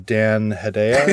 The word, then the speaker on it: Dan Hedaya: But my possibly my favorite thing about how that sort of montage of tournament life is introduced is the the tournament director, Dan Hedaya. Is that how you say his name? – Yep. Right? Yeah, I believe it is.